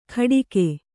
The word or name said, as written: ಖಡಿಕೆ